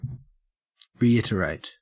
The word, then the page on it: reiterate: Australian English